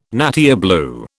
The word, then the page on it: nattier blue: British English